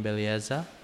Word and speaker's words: Belleza: And right now I'm joined by Kathleen Belleza. Is that how I am I pronouncing it right? – Yeah, that's how you pronounce it.